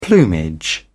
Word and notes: plumage: British English

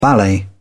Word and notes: ballet: British English

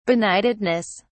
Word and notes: benightedness: British English

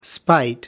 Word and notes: spite: American English

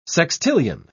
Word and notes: sextillion: British English

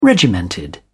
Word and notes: regimented: British English

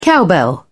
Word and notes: cowbell: British English

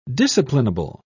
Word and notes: disciplinable: British English

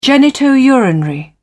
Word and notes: genito-urinary: British English

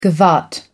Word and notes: gavotte: American English

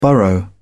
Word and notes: burrow: British English

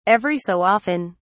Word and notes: every so often: British English

